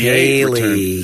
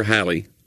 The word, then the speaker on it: Halley: He was the first to correctly predict the return date of a comet by predicting the 1758 Haley. – Return. Edmund? – Haley. – Haley or Halley? – Haley. – It's H-A-L-L, so it should be a short A-L-L.